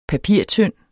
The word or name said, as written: papirtynd